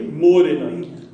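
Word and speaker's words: mōrena: After me, morena. – Morena. – Morena. – Morena. – So the emphasis has to be on that first syllable for it to sound, come out the right way.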